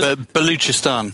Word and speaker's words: Balochistan: But I look at Balochistan, is that how you pronounce it? – Baluchistan.